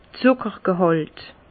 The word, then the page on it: d'r Zuckergehàlt: Bas-Rhin; Herrlisheim